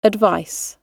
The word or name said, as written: advice